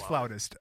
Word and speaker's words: flautist: We're going to talk about flutes and flautist. Is that how you say it? A flautist? – It is flautist.